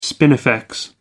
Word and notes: spinifex: American English

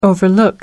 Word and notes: overlook: American English

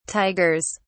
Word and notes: tigers: British English

